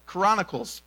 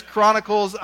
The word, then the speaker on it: Chronicles: you are adding an entire syllable to that word, okay? So I think it's Chronicles. I'm going to just fight through insecurity and try not to say it much.